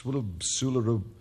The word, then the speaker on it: SWLABR: And the reason that I can't tell you the title of that one is I can't pronounce it. It's Swularu. Oh, forget it. S W L A B R.